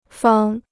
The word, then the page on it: 方: fāng